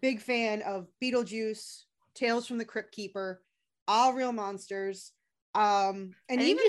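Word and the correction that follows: Aaahh!!! Real Monsters: you said that wrong. Shouldn't it be like, ah, Real Monsters? – It should.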